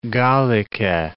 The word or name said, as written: Gallice